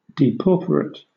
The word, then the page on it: depauperate: British English